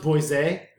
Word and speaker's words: Boise: It's pronounced Boise.